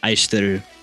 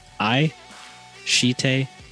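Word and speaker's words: Aishiteru (愛してる): Aishiteru. – Aishiteru. Sounds like I said, I shit in a room. – Yes, it's exactly like that.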